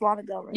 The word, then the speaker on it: Lana Del Rey: So, minus that, it was a national anthem by Lana Del Rey? I don't know how to pronounce her name. – Yeah, it's Lana Del Rey.